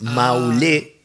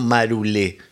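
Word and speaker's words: Maroulé: Y de hecho la pronunciación es Maulé y no Marulé.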